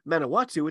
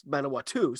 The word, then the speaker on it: Manawatu: Anyway, Manawatu versus Counties Manukau, and first of all, as I've kind of, you know, tacitly implied here, I've been pronouncing it wrong this whole time. It's not Manawatu, it's Manawatu, so sorry about that.